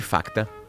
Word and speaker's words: Facta: Facta, empréstimo rápido e fácil. Sempre lembrando que Facta se escreve com C mudo.